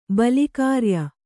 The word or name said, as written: ಬಲಿಕಾರ್ಯ